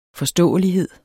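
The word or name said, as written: forståelighed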